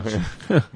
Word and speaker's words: Bianchi: Desculpa a pronúncia. – Bianchi.